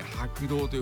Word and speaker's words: 白道: お月様が渡ってゆく道は白い道と書いて白道と呼ぶんですよ。